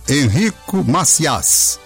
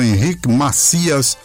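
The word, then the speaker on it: Enrico Macias: Aí, pesquisando, vi um cantor chamado Henrico Macias. Alguns chamam Henrico Macias, pronuncia em francês. Outros chamam Henrique Macias, mas eu preferi Macias, que é como eles pronunciam lá.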